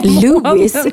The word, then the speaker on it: Louis: Louis Armstrong var först på Louis, Louis heter han, man uttalar inte S-et.